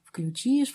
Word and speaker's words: включишь: Конечно, существует ряд правил, допустим, вот что в глаголах, оканчивающихся на «ить», при спряжении ударение падает на окончание. «Включишь», «включим», «включите», «включат».